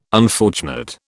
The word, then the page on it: unfortunate: British English